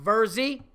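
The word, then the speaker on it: Virzi: That's V-I-R-Z-I, pronounced Verzi.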